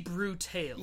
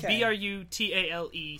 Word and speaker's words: Brutale: Now I, am not totally sure I pronounce this, but from what I understand, the sexy Brutale. – Yeah, okay. It's B R U T A L E. Some people say Brutale, some people say Brutal.